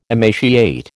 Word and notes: emaciate: American English

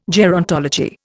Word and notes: gerontology: Indian

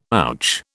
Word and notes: ouch: British English

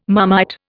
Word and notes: Marmite: Indian

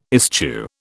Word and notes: eschew: British English

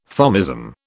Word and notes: Thomism: British English

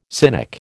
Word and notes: cynic: American English

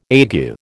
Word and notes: ague: American English

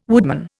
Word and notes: woodman: Indian

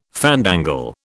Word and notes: fandangle: British English

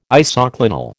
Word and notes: isoclinal: American English

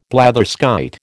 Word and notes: blatherskite: American English

